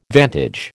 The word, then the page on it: vantage: American English